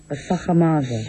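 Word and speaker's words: Sacher-Masoch: Sacher Masoch.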